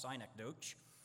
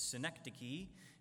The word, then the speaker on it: synecdoche: And believe it or not, it's not pronounced synecdoche. It's synecdoche. It's not a town in upstate New York.